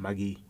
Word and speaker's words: Maggi: You, know, you have to actually say it like that, magi.